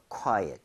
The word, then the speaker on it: quiet: So quiet 이렇게 해주고요. 너무 quiet 하지 마세요. – 네. – U의 강세를 좀 찍어서 읽을 때 거기 강세 주면요. ot보다도 at에 가깝게 오히려 네. 발음이 될 겁니다.